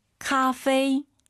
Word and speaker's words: 咖啡: 카페이 아니고 카페이도 아니고 커피 다시 咖啡. – 카페이 삼성삼성이니까 앞에가 이성입니다. 니 올라가야죠. 니 마이 마이는 조금 더 올라가면 안 돼요. 뒤에가 일성이니까 니 마이 카페이 이런 발음 디테일 기억하시고요.